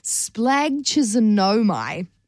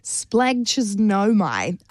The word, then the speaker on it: splagchnizomai: Splagchisonomai. It's spelled S-P-L-A-G-C-H-N-I-Z-O-M-A-I. Splagchisonomai.